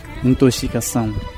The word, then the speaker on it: intoxicação: A consoante X da palavra intoxicação articula-se QS, uma vez que na sua origem está o adjetivo tóxico. Este adjetivo provém do grego toxikos, pronunciado originalmente com o som QS.